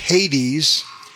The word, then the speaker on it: Hades: Hades, pronounced in English Hades.